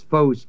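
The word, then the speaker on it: phos (φῶς): and the word light is phos, P-H-O-S, long O,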